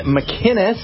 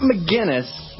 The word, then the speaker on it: McInnes: My name is Gavin McInnes. Not McGinnis, McInnes. It's a hard C.